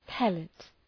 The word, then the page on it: pellet: ‘pelıt